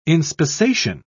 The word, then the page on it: inspissation: British English